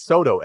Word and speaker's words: Soto: But it's probably not spelled like the name. – It's Soto. Soto. Soto.